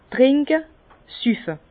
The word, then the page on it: trinke: Bas-Rhin; Herrlisheim